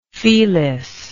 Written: felis

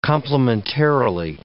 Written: complimentarily

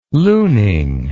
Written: Luening

